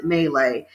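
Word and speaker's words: Maileg: It's M-A-I-L-E-G, but you pronounce it Melee.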